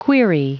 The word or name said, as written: query